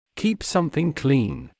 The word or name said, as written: keep something clean